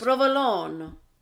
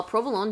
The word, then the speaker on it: provolone: First of all, provolone doesn't have a, like, you don't pronounce the vowel at the end.